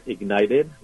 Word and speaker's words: IGNTD: So I run a company called Ignited. That's spelled I-G-N-T-D. It's like the word Ignited with a couple of letters, a couple of vowels missing, I-G-N-T-D.